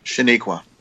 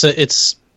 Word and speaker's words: Sonequa: But they did release some stuff about the character that Sonica, if I pronounce her name right, Sonica Martin Green, her character. – Shaniqua. – Is it Shaniqua? – I think it's Sonequa.